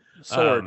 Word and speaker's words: Sjoerd: Sword.